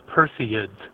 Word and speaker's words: Perseids: Well, first of all, as an amateur astronomer, I'll tell you that the meteor shower is the Perseids. – Persian. – Three syllables.